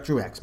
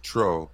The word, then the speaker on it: Truax: Why do I keep saying Track? Yeah, you're right, Truax, my bad. – No, it is Truax, but I believe that's a French name and it's pronounced Tro.